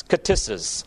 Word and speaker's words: ktisis: In Greek, it is spelled K-T-I-S-I-S, katisis.